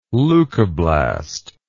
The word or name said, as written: leukoblast